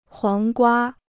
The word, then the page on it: 黄瓜: huáng guā